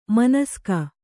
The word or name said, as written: ಮಾನಸಿಕ